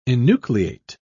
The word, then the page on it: enucleate: British English